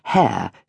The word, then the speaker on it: Heyer: and their father changed the pronunciation of his name to rhyme it with Hare during the war years, when the Windsors and Mountbattens were doing the same sort of thing.